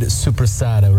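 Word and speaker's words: soppressata: So that's my father's famous homemade supersada recipe. – Supersada. – Yep, supersada. – Supersada. – That's the correct Italian pronunciation.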